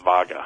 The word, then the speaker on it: vaga: How do you pronounce it? Cyanotis or Cyanotis? – Cyanotis vaga. – Vaga, okay.